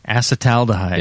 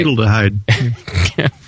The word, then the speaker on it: acetaldehyde: Acetaldehyde. – Yeah, all right. Good. I want to test you both, Palmer. You better be on your toes. – Okay. Acetaldehyde.